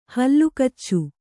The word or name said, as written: ಹಲ್ಲುಕಚ್ಚು